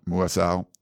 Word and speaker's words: Muasau: And then they have linebacker Darius Mausu. Is that how you say it? – Muasau.